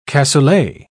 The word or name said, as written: cassoulet